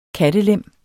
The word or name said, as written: kattelem